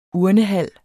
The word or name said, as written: urnehal